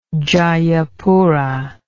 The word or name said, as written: djajapura